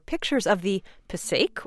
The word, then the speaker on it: Passaic: Or, as listener Bill Walker, who was born in the city of Paterson, New Jersey, corrects us, Passaic. He writes, Passaic, two syllables. The only people who use the three-syllable pronunciation are New York announcers who never bother to learn the correct way to say this word.